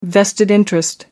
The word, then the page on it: vested interest: American English